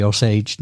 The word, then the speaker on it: Osage: But I think the big thing we're going to be able to talk about tonight is your heritage with the Osage Nation.